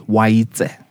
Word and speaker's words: Waitse: Now, the way that it's spelt versus the way that it's pronounced are totally different. – Really? – Right? So the name is Waitse. – Waitse. – Yeah, Waitse. So T-S-E at the end, Waitse.